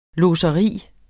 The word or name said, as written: lodseri